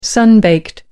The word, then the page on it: sun baked: American English